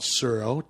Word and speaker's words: Tsuro: It's called Suro, T-S-U-R-O, and the T is silent.